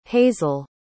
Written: hazelle

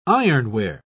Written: ironware

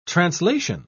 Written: translation

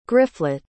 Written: griflet

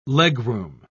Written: legroom

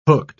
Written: hooked

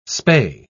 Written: spay

